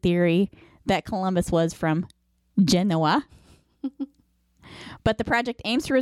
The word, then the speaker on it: Genoa: You mean Genoa? – Yes. Did I say it wrong? – Yeah. – I'm sorry. All right. You got me. – Ding, ding, ding, ding. – Genoa.